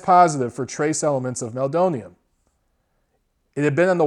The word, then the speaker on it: meldonium: I've also heard it pronounced Meldonium. I've heard it pronounced a bunch of different ways. I say Meldonium.